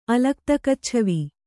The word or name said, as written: ಅಲಕ್ತಕಚ್ಛವಿ